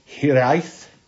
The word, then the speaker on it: hiraeth: It's hiraeth, hiraeth. You have to roll the R to say it properly.